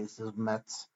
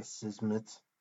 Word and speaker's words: Szmyt: His last name is S-Z-M-Y-T. So there's a lot of consonants in a row. I was not sure how to pronounce that, but I think it's Andre Zmetz.